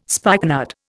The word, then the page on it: spikenard: Indian